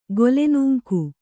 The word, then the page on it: ಗೊಲೆನೂಂಕು: Kannada